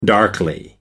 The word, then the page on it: darkly: American English